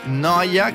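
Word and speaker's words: NO1A: Noia, che si scrive però N-O-14.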